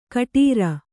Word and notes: ಗೊಟರೆ: Kannada

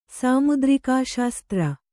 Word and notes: ಸಾಮುದ್ರಿಕಾಶಾಸ್ತ್ರ: Kannada

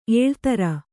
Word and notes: ಎಳೆತರ್: eḷetar

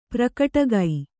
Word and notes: ಪ್ರಕಟಗೈ: prakaṭagi

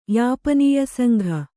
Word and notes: ಯಾಪನೀಯಸಂಘ: Kannada

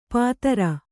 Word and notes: ಪಾತರ: Kannada

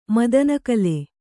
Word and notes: ಮದನಕಲೆ: madana kale

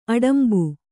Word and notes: ಅಡಂಬೆ: Kannada

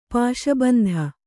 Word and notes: ಪಾಶಬಂಧ: Kannada

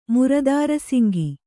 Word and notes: ಮುರುದಾರಸಿಂಗಿ: murudāra singi

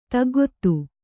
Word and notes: ತಗ್ಗೊತ್ತು: taggottu

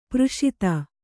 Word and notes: ಪಾರ್ಷದ: kannada

